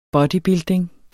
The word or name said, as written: bodybuilding